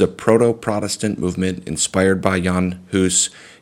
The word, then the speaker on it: Jan Hus: Sometimes people pronounce it John Huss or Jan Hus. It's Jan Hus.